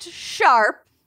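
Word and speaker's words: S#arp: The K-pop group S hashtag or pound sign ARP. – Pound sign. – pronounced sharp.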